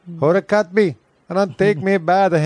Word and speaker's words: Kotb: Her last name is spelled K-O-T-B. Kid on the block. – K-O-T-B? – K-O-T-B, Kotb. – That can't be right. – It is Kotb. – Is that how you say it, though? – I think so. Yep, Kotb.